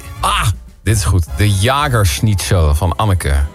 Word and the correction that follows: Jägerschnitzel: Het is dan misschien de Jägerschnitzel, want zo spreek je dat.